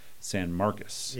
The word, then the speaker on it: San Marcos: the pronunciation is San Marcos.